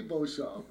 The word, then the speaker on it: Beauchamp: Actually, you say Beauchamp. Everybody else cannot pronounce French, so they say Beauchamp or Beauchamp.